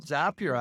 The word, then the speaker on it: Zapier: It's called Zapier, actually, because it rhymes with happier, I was told.